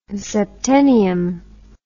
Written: septennium

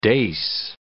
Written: dace

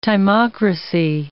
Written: timocracy

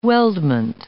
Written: weldment

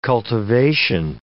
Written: cultivation

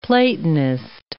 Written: Platonist

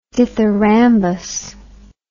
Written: dithyrambus

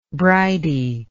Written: bridey

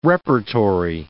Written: repertory

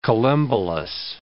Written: collembolous

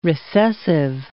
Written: recessive

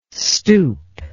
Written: stupe

